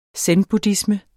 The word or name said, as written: zenbuddhisme